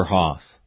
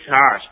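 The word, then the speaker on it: Hass: The most popular California avocado is the Haas, frequently mispronounced and misspelled as, is it Haas or Haas? – It's Haas, but it's H-A-S-S. – So it sounds like Haas. – It sounds like Haas. – Right, okay. – It's Haas avocado.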